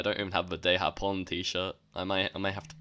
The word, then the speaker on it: Los Ingobernables de Japón: Japón. – Los Ingobernables de Japón is how you say it.